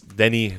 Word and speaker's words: Denis: I know it's Denis and not Dennis.